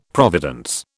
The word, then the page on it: providence: British English